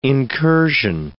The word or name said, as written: incursion